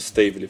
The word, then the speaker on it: Стейвли: Она произносится как именно Стейвли, фамилия ее.